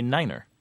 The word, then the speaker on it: niner: It sounds too much like five unless you add that second syllable on the end, so you don't say nine, you say niner.